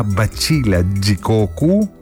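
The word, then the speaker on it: batida de coco: Σίγουρα αν είχαν μπατίτα ντε κόκο ή αλλιώς όπως προφέρεται στα πορτογαλικά μπατσίλα τζικόκου, δεν θα είχαν πρόβλημα.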